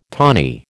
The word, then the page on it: tawny: American English